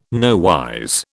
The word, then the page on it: nowise: British English